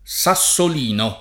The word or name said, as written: sassolino